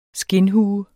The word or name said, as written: skindhue